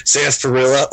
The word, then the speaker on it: sarsaparilla: So I, like how we're, we're we're narrowing in on the more important parts of the episode, like sarsaparilla. Oh, I said it right.